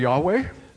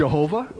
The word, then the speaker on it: Yahweh: How do you pronounce Yud-Heh-Vav-Heh? – Wie soll man das vom Hebräischen her aussprechen? – The consonants from Hebrew. – Wo man nur die Konsonanten im Hebräischen hat. – You can say Yahweh. – Du kannst Yahweh sagen. – You can say Jehovah.